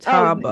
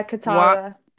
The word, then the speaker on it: Catawba: Catawba land. – Catawba. – Oh, yeah, Catawba.